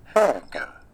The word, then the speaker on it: hygge: There's just a great variety of sound throughout it that is just, it adds to that Higgy effect. – Higge. It's pronounced. See if we can get this to play. – Hooga.